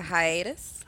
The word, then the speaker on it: hiatus: Dr. How do you say it? – A hiatus. – Hiatus. – Oh, so you really were saying hyenas? I thought you was joking. – Yeah, he's saying hiatus.